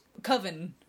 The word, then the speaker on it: coven: He goes, I believe it's pronounced coven. – Coven sounds like oven.